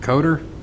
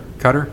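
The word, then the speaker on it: Dirk Koetter: What is it? Coder? Is that how you say it? – Cutter. – Is that how you say it? – Yep.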